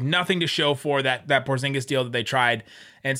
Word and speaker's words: Porzingis: You said it right when you said Porzingis, so we'll go with that.